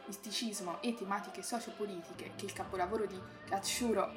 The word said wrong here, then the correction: Katsuhiro: Katsuhiro! Devi dirlo bene. – Cioè, ragazzi, io mi scuso perché in realtà... – Katsuhiro! Ma devi mettere l'accento su Hiro! – Ecco, hai capito? Ci devi mettere l'enfasi quando... Katsuhiro! Perché altrimenti italianizzi troppo e non va bene.